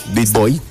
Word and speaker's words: Big Boy: Ese tema ya tiene una historia muy particular, Big Boy. ¿Se dice así? – Big Boy, sí.